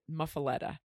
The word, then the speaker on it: muffuletta: Here we go, the muffuletta sandwich. What it started out as is the loaf of bread itself is muffuletta. There's a couple different ways to pronounce it, but basically that's it, muffuletta.